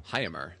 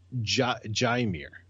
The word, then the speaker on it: Jaime: Before you finish, how did you say his name? – Jaime. – Now, I've heard it. The two times I've heard his first name pronounced is with the J, Jaimir. – There's no way that that's correct.